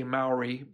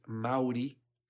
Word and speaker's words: Maori: We say Maori, but the correct pronunciation is Maori.